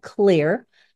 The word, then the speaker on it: Xlear: which is appropriately called clear, but I always call it X-Lear because it's spelled X-L-E-A-R.